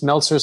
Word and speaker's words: Meltzer: And it's Meltzer spelled like seltzer that you drink, but with an M like Mary.